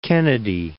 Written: Cape Kennedy

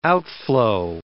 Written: outflow

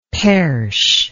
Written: perche

